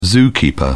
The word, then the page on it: zookeeper: British English